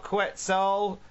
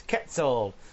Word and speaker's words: Quetzal: Quetzal. The U is silent. Quetzal.